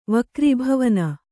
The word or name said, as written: ವಕ್ರೀಭವನ